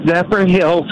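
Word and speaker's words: Zephyrhills: Zephyrillo, Florida. Am I saying that right? – No, Zephyr Hills, Florida. – Oh, Zephyr Hills. Two words.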